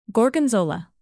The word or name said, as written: gorgonzola